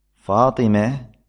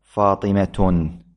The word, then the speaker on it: فاطمة: فاطمة لا تقول فيها فاطمة، وإن كان البعض لعله يتلفظ بهذا اللفظ لكنه خطأ.